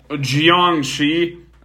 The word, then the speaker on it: Jiangshi: What's that Jiwangji mentioned? I know I butchered that pronunciation. – So it's a Jiangshi.